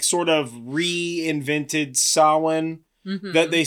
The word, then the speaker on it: Samhain: Also, and I'm going to bring this up every time anybody ever mentions this, it should be pronounced Samhain. Deal with it.